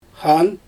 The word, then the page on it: xan: Xyzyl